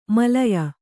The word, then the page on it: ಮಲಯ: malaya